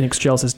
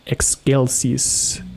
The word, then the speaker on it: excelsis: Because in church Latin, it is in excelsis Deo. In a classical Latin pronunciation, it would be in excelsis Deo. – Excelsis. – Because, you know, Latin C is a hard C sound.